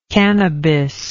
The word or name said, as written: cannabic